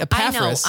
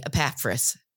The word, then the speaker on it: Epaphras: But anyway, Epaphras. I want to add an extra P. I want to do that because I see the double P and it makes me think pap, but it's so anyway.